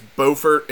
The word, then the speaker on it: Beaufort: It is Beaufort in North Carolina. They're both beachy, yep, like coastal towns, but they pronounce them differently.